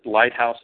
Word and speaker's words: Lighthouse: My website is R Lighthouse, and Lighthouse is spelled LIGHTHOUSE.